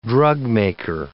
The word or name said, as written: drugmaker